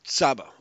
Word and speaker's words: Csaba: Csaba, okay. – C is silent. – Okay. Oh, Csaba, okay.